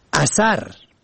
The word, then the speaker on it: اثر: اثر الف سه ر به فتح اول و دوم، نشانه اثر الف سه ر با کسر اول و سکون دوم، عقب آخر با کسر دوم، پسین معنا می دهد.